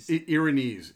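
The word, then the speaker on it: Erinyes: Erinneth is how you actually pronounce that.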